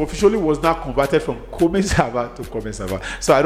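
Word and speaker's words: Comment ça va: But in French, it is pronounced Koma Sava, which means how are you.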